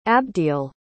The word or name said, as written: abdiel